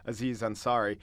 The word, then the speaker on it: Aziz Ansari: It's Aziz Ansari.